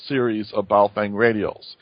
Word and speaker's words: Baofeng: It's called a Bale, fang, or fang. Uh, a lot of people pronounce it differently. It's B A O F E N G.